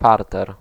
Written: rez-de-chaussée

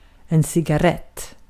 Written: cigarrett

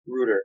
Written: router

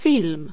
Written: pellicule

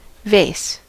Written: pot

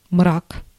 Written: darkness

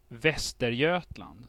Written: Västergötland

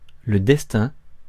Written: fortune

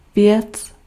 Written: chose

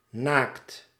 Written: crude

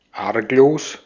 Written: harmless

